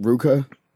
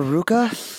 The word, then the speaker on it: RVCA: And shit like isn't that pronounced Ruka? – Oh, I don't know. – Because everybody does that with the. – Yeah. Oh, is that a Ruka? – I think it's Ruka. – It'd be Raka, right?